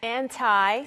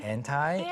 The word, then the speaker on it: anti: Anti는 물론 두 가지 발음이 있죠. – Anti. – Anti. – Anti. – Anti. 편하신 대로 발음하시면 되겠고 mm-hmm.